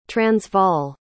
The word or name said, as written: transvaal